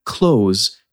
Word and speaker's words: clothes: Here too, you can and should take the shortcut and pronounce the word close, as in, please close the window, I'm getting cold.